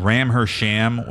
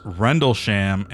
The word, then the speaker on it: Rendlesham: If you thought Ram Her Sham was the latest video from Bang Bros, stick around. You're wrong. Apparently it's pronounced Rendlesham and it's a fucking UFO story.